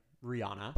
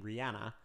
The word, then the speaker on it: Rihanna: Going to be totally honest, I have always pronounced her name Rihanna, but I've heard in several interviews she pronounces her own name Rihanna.